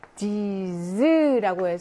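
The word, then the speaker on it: dix: 요거는 숫자 디스가 단독으로 발음하면 디스고요. 뒤에 모음이 나오게 되면 디즈라고 해서 이렇게 즈 발음으로 바뀌죠.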